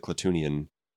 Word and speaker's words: Klatooinian: Well, because it's spelled Klatooinian, but in Book of Boba Fett, everybody misses that I after the double O. So they all pronounce it Klatooinian. But it's Klatooinian.